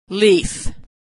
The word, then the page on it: leaf: female